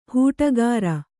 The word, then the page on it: ಹೂಟಗಾರ: hūṭagāra